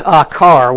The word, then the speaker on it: akar: The word for trouble is akar. We would spell it in English, A-K-A-R.